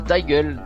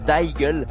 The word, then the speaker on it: DYGL: qui s'appelle Daigle. Daigle, Donc ça s'écrit D Y G L,